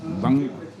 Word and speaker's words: Bang: Bang. Bang, c'est en fait c'est la prononciation déformée de banc en Wolof.